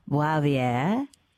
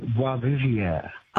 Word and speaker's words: Boisvivier: He's currently, how do you even say this? Wavier? – You don't say it like that. – Mate, how do you, say it? – You say it like Boisvivier.